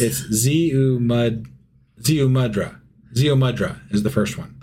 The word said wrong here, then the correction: Ziusudra: Sorry. Oh, no, that's what it rhymes with. Shit. No, that's not right. – Oh, my God. – Ziusudra. Ziusudra. – Ziusudra? Rhymes with mudra? – Ziusudra.